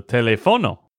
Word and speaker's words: teléfono: Bueno, muy bien, pero pusiste mal el acento en algunas de estas palabras. Di métodos. – Métodos. – Telégrafo. – Telégrafo. – Teléfono. – Teléfono. – Y tú sabes que es teléfono, pero como estabas leyendo, no sé qué te pasó, y dijiste teléfono.